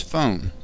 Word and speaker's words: FON: One such company is Phone, pronounced F-O-N, spelled F-O-N, which